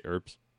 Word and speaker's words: herbs: I do it with a silent H. – So you say herbs? – I do say herbs. – Okay. Herbs. – Just like a knife. The silent K, the silent H, you know, English.